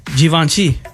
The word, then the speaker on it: Givenchy: Givenchy. – I've never even heard of that brand. – I guess you're supposed to pronounce it with a Z. According to this, it's Z-H-E-E-V-O-N-S-H-E-E. Givenchy.